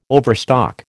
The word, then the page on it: overstock: American English